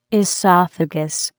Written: esophagus

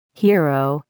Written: hero

